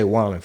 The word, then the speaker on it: Wallen: Wallen, yeah. – Morgan Wallen thing. – Some people say Waylon because it's like Waylon. It looks like Waylon Jennings' name, but Morgan Wallen. – Okay, I'll just say Wallen.